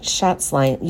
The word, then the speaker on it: Schatzline: Nate Schatzline.